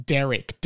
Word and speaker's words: Derrick: Lele has said Derek, D-A-R-R-I-C-K, which may be you, like, interpreting my Australian accent. I am saying Derek, D-E. It's not Derek. And it's also not Derek spelt D-E-R-E-K. Some people are spelling it like that. But it is Derek, D-E-R-R-I-C-K.